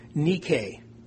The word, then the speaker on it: nike: The word overcome is translated from the Greek word nike. N-I-K-E. We pronounce that Nike.